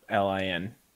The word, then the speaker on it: Kholin: Kholin.